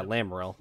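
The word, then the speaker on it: Lamaril: Gaz and Lamoral? Is that his? – Lamoral? – Yeah. – Mm-hmm. Yeah. – Lamoral? – Lamoral? Yeah, Lamoral. That's how you say it.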